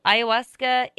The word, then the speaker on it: ayahuasca: This is a Spanish word. So, I don't know. It definitely is pronounced like a Spanish word. So ayahuasca is A-Y-A-H-U-A-S-C-A.